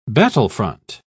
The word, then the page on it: battlefront: British English